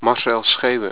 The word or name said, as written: Marcel Schewe